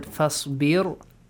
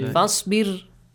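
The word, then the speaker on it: فاصبر: Look, you pronounced the word Fasbir heavily, while you have to pronounce it lightly. Fasbir.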